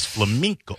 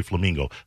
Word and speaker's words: flamenco: Into this flamenco. I have to say it right because a lot of people say flamingo. Flamenco.